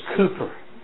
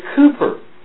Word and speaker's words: Cowper: But it's not Cowper, it's Cooper. Did you know that? Have you ever heard that? It's actually Cooper. Because the W is in Old English is oo. So his name is Cooper, William Cooper. It's spelled C-O-W, but they call it in London, they call it Cooper.